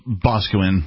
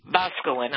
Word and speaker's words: Boscawen: Can Ian get credit for the time he's currently serving at the county jail in Bowcowan? Am I pronouncing it properly? – Boscawen, yeah. – Boscawen, I beg your pardon.